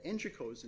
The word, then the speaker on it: Engico: And then at Engico's insistence, uh, and that's the pronunciation we used at trial was Engico, uh,